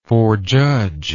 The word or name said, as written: forjudge